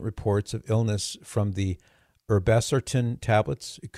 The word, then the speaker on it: Irbesartan: The FDA this past week announced that Lupin Pharmaceuticals is recalling batches of its Irbesartan tablets, and it's spelled I-R-B-E-S-A-R-T-A-N, Irbesartan, and Irbesartan and hydrochlorothiazide tablets.